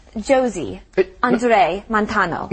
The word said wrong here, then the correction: José: No. Not Josie. – But it looks like Josie. – I know it looks like Josie, but in Spanish, the J is like a huh.